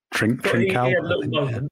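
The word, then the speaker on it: Trincão: Sometimes the Barcelona lad, is it Trincao? Probably yes. Clean the pronunciation. – Trincao.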